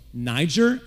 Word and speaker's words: Niger: So Niger, and I am pronouncing it correctly because that is how you pronounce it in terms of what Scripture's saying.